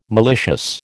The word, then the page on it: malicious: American English